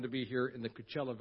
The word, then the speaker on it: Coachella: Coachella. Does that rhyme with anything? Coachella, Luella, I don't know. Nutella, thank you.